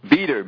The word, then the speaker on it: Beter: How do you spell that? – Beter. B-E-T-E-R. It's Peter Beter.